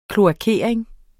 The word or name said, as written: kloakering